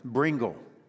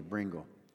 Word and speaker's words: Brengle: Have you ever heard of Samuel Bingle? So, you ever have that moment where you think, I don't think I got his name right? Bringle. B-R-E-N-G-L-E, Bringle.